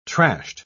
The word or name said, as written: trashed